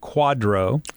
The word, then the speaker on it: QDRO: It's a QDRO, and we pronounce it quadro.